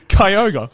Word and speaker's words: Kyogre: Kyogre. – Yeah, something like that. K-Y-O-G-R-E.